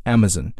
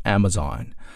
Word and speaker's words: Amazon: 英音当中呢一般会把后面这个 Z-O-N 这个地方读成 Zen 啊 Amazon。比如说每次读的这个词的时候读的都是 Amazon 啊，这是一个很经典很标准的英式发音。 Right. 那么美国人读的时候呢，往往他会把后面那个 Z-O-N 读成 Zon 所以是 Amazon.